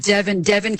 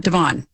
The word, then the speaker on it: Devin: And Devin Key, like Kevin, not Devon. Devin. – Right. Yes. Devin Key. – Rhymes with Kevin.